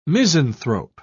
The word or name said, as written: misanthrope